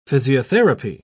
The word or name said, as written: physiotherapy